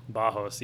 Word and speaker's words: Barros: So it's like spelled Barros, but it's said Bajos, right? – Bajos, yeah.